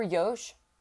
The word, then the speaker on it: Yosh: Super Yosh, with a long O is their username, said their title was Super Yosh here again.